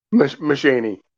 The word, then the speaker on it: Mushaney: Mushaney.